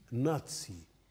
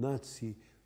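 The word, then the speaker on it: Nazi: سماه خصومه بالحزب الناتسي لأن اسمه بالألمانية فيه تكرار لصوت تس، ناتسيونال سوتسياليستيشي دويتشا أربايتر بارتاي، أي حزب العمال الاشتراكي الوطني الألماني. من هذا الصوت بلغته الألمانية سماه الأوروبيون المعادون للحزب النازي ناتسي، ونطقوه كالألمان ناتسي، يكتبونه بحرف زد وينطقونها تس مثل الألمان.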